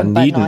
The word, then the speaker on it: Dunedin: Ja, und es wird da Dunedin ausgesprochen. Dazu. Es gibt so viele, die sagen Dunedin oder sonst irgendwas.